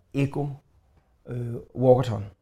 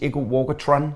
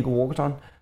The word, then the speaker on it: Eko Walkathon: Eko har noget hvert år, der hedder Eko Walkathon, som er rigtig måde at udtale det på. Jeg har fået at vide, at jeg er kommet til at sige Eko Walkathon sidste gang, og det lyder federe, men det er forkert. Det er Eko Walkathon.